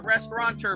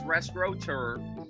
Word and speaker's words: restaurateur: It's not restaurateur, people. It's restaurateur. Learn how to spell it. Learn how to say it.